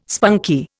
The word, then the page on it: spunky: Indian